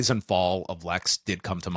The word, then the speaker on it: Pfol: Fall. P-F-O-L. Silent P. A rarity.